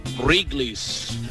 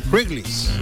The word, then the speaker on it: Wrigley's: La pronunciación correcta de esa compañía es Wrigley's. – Wrigley's.